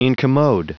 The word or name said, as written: incommode